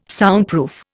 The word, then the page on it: Soundproof: Indian